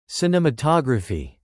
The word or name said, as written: cinematography